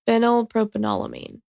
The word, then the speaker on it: Phenylpropanolamine: Phenylpropanolamine.